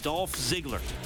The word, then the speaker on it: Dolph Ziggler: Now, make sure I'm saying this right, Kellen. Dolph Ziggler. – Correct. – Dolph Ziggler. That's right. That's correct? – 100% correct. Yes.